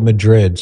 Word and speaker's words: Madrid: And by the way, my last name is spelled Madrid.